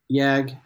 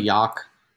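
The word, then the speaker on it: Jagd: Yag. It's really Yak if you want to say it like German way.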